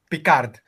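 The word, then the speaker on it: Picard: Πώ προφέρετε. Κέρκορ Πικάρντ. Δεν κάνουμε κακέ ερωτήσει στο chat. Πικάρντ. Προφανώ Πικάρντ. Ξεκάθαρα Πικάρντ, παιδιά.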